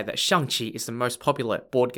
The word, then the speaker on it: Xiangqi: Xiangqi. Xiangqi is spelt X-I-A-N-G-Q-I.